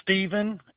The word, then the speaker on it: Steven: Stephen, S-T-E-V-E-N.